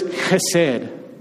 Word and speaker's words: chesed: It's this Hebrew word that you might have heard before, and the word is chesed. That's the way you say it okay? And I know because I've been corrected. The word, the Hebrew word here is chesed, okay? You, you can spell it H E S E D or C H E S E D, chesed, okay?